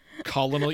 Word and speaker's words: colonel: Colonel? You didn't just say colonel? – But that's how it's spelled. – Kentucky Fried Chicken, courtesy of Colonel Sanders. – Well, why would they put an R in there if there's no R in the word?